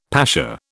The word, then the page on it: pasha: British English